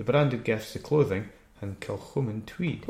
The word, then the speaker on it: Kilchoman: Kilchoman.